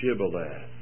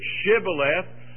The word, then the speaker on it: Shibboleth: They asked him to say, "Shibboleth."